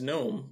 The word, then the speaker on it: GNOME: GNOME is spelled the same as gnome, like a garden gnome, but it's GNOME.